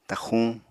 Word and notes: taxxuñ: apatani

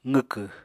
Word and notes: qwkw: apatani

